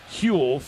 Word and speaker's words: Houle: Eventually taken by Huell for Lindenwood. – Oh, that's what I was going to say. I got a text. – Yeah. – H-O-U-L-E is a French name. The H is silent. – Oh, Huell. – Huell. – Well, according to the Lindenwood notes, it's Huell. H-U-L-E is how it's pronounced.